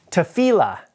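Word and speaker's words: tefillah (תפילה): This word is prayer, and it's pronounced from here, right to left, tefillah, tefillah.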